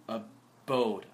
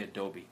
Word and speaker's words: abode: I can't pronounce that word. Abode. I always want to say adobe.